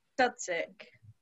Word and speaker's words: Dudzik: Dudzik. – It is just Dudzik. – Literally, as it's spelled.